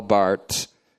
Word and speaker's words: Barth: Karl Bart, his name is pronounced, it looks like Barth, but it's actually not pronouncing the H.